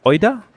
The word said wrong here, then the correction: Ojeda: Miguel Ojeda.